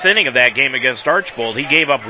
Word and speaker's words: Archbold: It's spelled A R C H B O L D. And I heard so many media members this past week, especially in Mansfield, pronounce it Archibald. There's no I and there's no A in the, in the second part of it. It's Archbold and I come from Northwest Ohio,